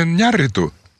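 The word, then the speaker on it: Iñárritu: Я говорю сейчас про Алехандро Гонсалеса Иньярриту, чтобы вы поставили себе правильное ударение, пронзили свой мозг мыслью, что надо уважать фамилию человека, а не менять ему ударение произвольно.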